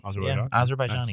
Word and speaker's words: Azerbaijani: That is brought to you by the Azerbaijani language. Did I pronounce that correctly? – Azerbaijani.